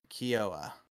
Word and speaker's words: Keough: Keough.